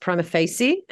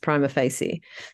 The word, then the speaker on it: Prima Facie: But in Australia, it's pronounced Prima Facie, and in law courts, it's pronounced Prima Facie. So that's the sort of one I go with.